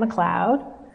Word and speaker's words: McLeod: Um, so, my name is Deanna McLeod.